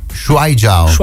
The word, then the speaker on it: Shuaijiao: Tutto questo per parlare di Shuaijiao.